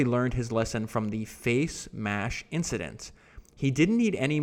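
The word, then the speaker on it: FaceMash: Mark had obviously learned his lesson from the face mash incident. I don't know why I can't say that word. I keep mispronouncing face mash. There we go.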